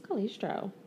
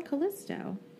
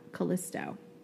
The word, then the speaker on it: Callisto: I have Callisto. I don't have an R in here. C-A-L-L-I-S-T-O. Callisto. – I swear my version says Callistro.